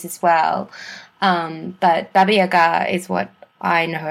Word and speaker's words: Baba Yaga: So you get people like Baba Yaga, Oh, wow, you actually pronounce it correctly. – Yes, people tend to say Baba Yaga, yeah, especially here in Australia.